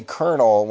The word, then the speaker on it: colonel: Why do we say colonel when it's spelled like C-O-L-O-N-O-L or whatever?